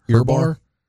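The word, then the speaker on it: Ohrnberger: He had Rich Earbar, I believe is how you say his name. – Earbar.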